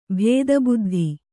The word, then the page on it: ಭೇದಬುದ್ಧಿ: bhēda buddhi